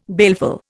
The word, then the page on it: baleful: Indian